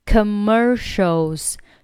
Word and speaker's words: commercials: 好，这里 I talk with you, talk with, talk 末尾可以不用发音，上面的 talk with me 一样的 talk with me, talk with you during the commercials, commercials 注意 commer mer 这里是有一个卷舌音的 er commer 后面 show show 这里呢它是有一个凹舌音啊，舌尖呢是往上齿龈方向去顶 show show commercial 一次是往后卷，然后呢一次是往上顶 commercials commercials。好，我们把整篇对话再慢速读一下。This soup is delicious.